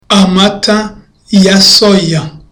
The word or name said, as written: amata ya soya